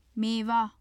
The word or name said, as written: mewa